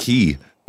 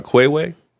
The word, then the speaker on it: quay: The word what? Key is spelled Q-U-A-Y. – Oh, yeah, yeah, there's a street in Toronto called the Queen's Quay. Queen's Key West, and it's spelt Q-U-A-Y. – Quay.